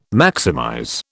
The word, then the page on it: maximize: British English